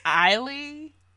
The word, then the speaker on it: Ailey: But I'm passing a blunt to Alvin Ali Ailey. Me and pronunciations on this podcast, I swear. – I think it's Eiley. – Eiley. – I don't know. – I want to say it's Eiley, but Alvin Ailey, incredible artist.